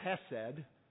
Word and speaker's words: chesed: I'll say hesed, but it can be spelled with a C there in front of it to make that chesed.